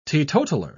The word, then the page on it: teetotaller: British English